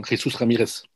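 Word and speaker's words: Jesús Ramírez: Du hast es ja schon so wunderbar spanisch äh, ausgesprochen. Jesus Ramirez.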